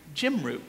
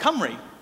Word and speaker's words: Cymru: The light flashes on in Beth's head and she goes, oh, no, Mr. Daniel, we pronounce that Cymru. And he goes, Cymru? She goes, yes, we pronounce that Cymru.